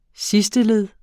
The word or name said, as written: sidsteled